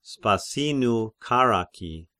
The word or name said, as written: Spasinu